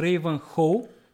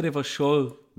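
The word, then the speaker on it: Revachol: Дори града, в който се развива действо, който ти би прочел като Рейвън Хол, се произнася Ревашол.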